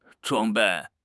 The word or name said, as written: 装备